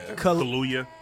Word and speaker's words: Kaluuya: Yo, Daniel Kaluuya is kind of getting into, like, that's how you say his name? Did I say his name? – Kaluuya. Yeah. There you go.